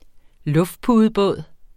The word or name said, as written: luftpudebåd